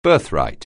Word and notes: birthright: anglais

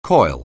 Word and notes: coil: anglais

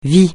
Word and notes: vie: francais

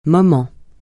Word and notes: moment: francais

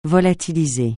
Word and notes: volatiliser: francais

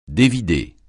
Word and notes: dévider: francais

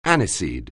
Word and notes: aniseed: anglais

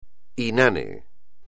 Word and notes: inane: espagnol

